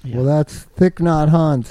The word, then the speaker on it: Thich Nhat Hanh: I don't even say his name right. It's Thich Nhat Hanh.